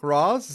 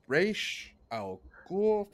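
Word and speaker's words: Ra's al Ghul: It's like, Roz, is that how you pronounce it? It's like, no. No, not how you pronounce it. It's Raish Al Ghul, for the love of God.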